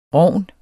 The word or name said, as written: rogn